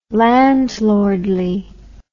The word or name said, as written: landlordly